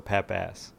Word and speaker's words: Pappas: It's like a long A, so Pappas.